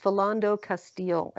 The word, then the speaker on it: Philando Castile: I want to correct a pronunciation. It's Philando Castile. I said his name wrong.